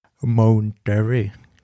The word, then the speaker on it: Monterey: Mountery. Jag vet inte hur det uttalas. Monterey.